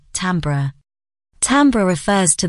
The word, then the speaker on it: timbre: Again, it's pronounced timbre. So, unfortunately, and in an exceedingly rare turn of events, Chris was right.